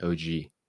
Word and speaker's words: oggi: OG is the Italian word for today. – I see. – In Italian, it's spelled O-G-G-I.